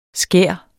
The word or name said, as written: skær